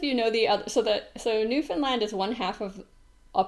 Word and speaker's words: Newfoundland: It's called Newfoundland and Labrador, and they both get final stress. And I think that helps a little to remember how to say it.